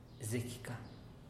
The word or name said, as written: zekika